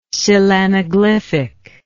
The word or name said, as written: coelanaglyphic